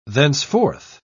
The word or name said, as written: thenceforth